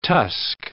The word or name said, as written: tusk